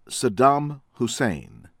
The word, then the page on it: Saddam Hussein: American English; from Iraq